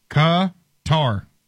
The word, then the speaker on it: Qatar: The most common and accepted English pronunciation of whatever the country's name is Qatar. Qatar is the phonetics.